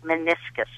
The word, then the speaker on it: meniscus: I have a torn meniscus. I'm not sure if I'm pronouncing that right. That is. You got it exactly correct.